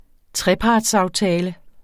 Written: trepartsaftale

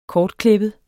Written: kortklippet

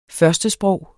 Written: førstesprog

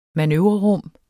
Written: manøvrerum

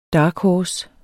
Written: dark horse

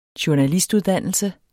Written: journalistuddannelse

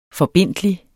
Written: forbindtlig

